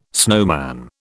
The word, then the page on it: snowman: British English